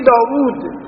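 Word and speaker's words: داود: داوود شما تو این نوشته هاتون هر موقع داوود را می نویسید دال می نویسید و الف و یه واو و دال، دوتا واو نمی نویسید داوود، منطقه تلفظ که می کنید می گه داوود، دوتا واو تلفظ.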